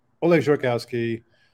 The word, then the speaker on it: Oleg Zhurakousky: Oleg Zhurakousky, I consider myself one of the original Spring Sourcians.